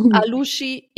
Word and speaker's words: Alushi: Alushi, ehemals Bayramay. – Super, ja. Gut ausgesprochen, Anja.